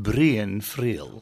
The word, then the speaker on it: Brian Friel: They pronounce him Brian Friel.